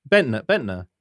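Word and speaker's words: Bendtner: Nicholas Bentner. – Oh, is it Bentner? – Bentner.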